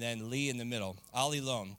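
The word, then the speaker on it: Ali lone (ἀλλήλων): It means alone, but it's you pronounce it with a li in the middle. So it's Ali lone.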